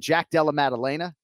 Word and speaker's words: Jack Della Maddalena: Jack Della Maddalena, Vanessa Demopoulos, Jasmine Jazz Divisius.